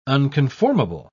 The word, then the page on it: unconformable: British English